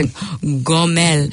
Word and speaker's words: Gomel: I should pronounce it with the Hebrew. It's a Gomel.